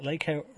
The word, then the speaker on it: LACO: I mean, LACO rhymes with Waco, but not with any sort of other sort of political undertones.